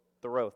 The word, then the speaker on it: Thoreau: Henry David Thoreau.